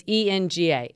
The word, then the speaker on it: Enga: Yeah, so it's ENGA, Enga. I love it. English and yoga.